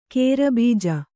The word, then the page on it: ಕೇರಬೀಜ: kannada